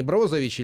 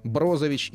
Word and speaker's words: Брозович: Естественно, Лука Модрич, Иван Ракитич, как и известны хорватские имена, э, вернее, имена и фамилии, да, здесь, как правило, ударение на первый слог, поэтому не Ракитич, не Ковачич, там, не Брозович или там э, Манжукич, а Ракитич, Кова... Ковачич, Брозович и Манжукич.